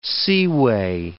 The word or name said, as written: seaway